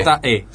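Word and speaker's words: Tom Zé: Tom Z-E, sí. Acento en la E, sí, al final.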